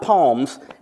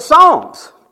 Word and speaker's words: Psalms: It's the book of Psalms, which means not palms, as Joe Biden said, but Psalms.